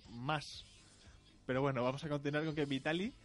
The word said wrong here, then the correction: Vitality: Vitality, perdón, se queda sin Hachani.